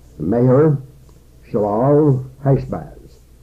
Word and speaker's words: Maher Shalal Hashbaz: And the second son has a long name, Maher Shalal Hashbaz. That's spelled M-A-H-E-R S-H-A-L-A-L H-A-S-H-B-A-Z, which means hastening to the spoil or hurrying to the prey.